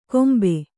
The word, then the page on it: ಕೊಂಬೆ: kannada